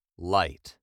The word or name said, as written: light